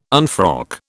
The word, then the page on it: unfrock: British English